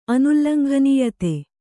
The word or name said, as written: ಅನುಲ್ಲಂಘನೀಯತೆ